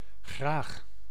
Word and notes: graag: [χʀaːχ]; /ɣraːx/

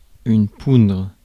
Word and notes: poudre: [pudʁ]; France

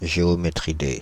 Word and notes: géométridé: /ʒe.ɔ.me.tʁi.de/; France (Île-de-France)

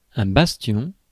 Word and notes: bastion: [bas.tjɔ̃]; France

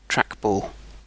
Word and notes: trackball: British English

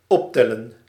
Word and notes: optellen: /op.tɛ.lǝⁿ/

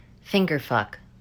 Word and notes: fingerfuck: /ˈfɪŋɡɚˌfʌk/; American English

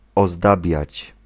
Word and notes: orner: France; IPA: [ɔʁ.ne]

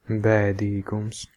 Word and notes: sadness: [ˈsæd.nɛs]; /ˈsæd.nɪs/

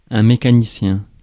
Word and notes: mécanicien: [me.ka.ni.sjɛ̃]; France

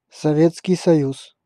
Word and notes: Советский Союз: /sɐˈvʲet͡skʲɪj sɐˈjʉs/; Sovetski Sojuz

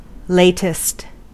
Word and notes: neu: [nɔɪ̯]

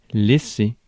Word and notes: laisser: France; [lɛ.se]

